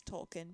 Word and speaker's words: Tolkien: Is it pronounced Tolkien? – Tolkien. I say Tolkien. – I say Tolkien. – Oh, mate, I think it is Tolkien. J.R. Tolkien, yeah, not Tolkien.